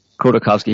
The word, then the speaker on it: Khodorkovsky: Khodorkovsky, he's the most well-known.